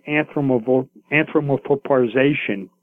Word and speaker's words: anthropomorphization: The better word for it is astrotheology, where it's the anthropomorphization, pardon my, you know, probably not the correct word. It's a long word, but no, you said it right.